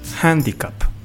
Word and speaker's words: handicap: Digamos, handicap. – No es con J, es con H. Handicap.